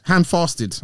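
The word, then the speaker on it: handfasted: When we first started to get hand fested, hand fasted, I'm saying it wrong, hand fasted, Chris proposed an agreement in which we'd be free to be sexual with other people during fair, but at no other time.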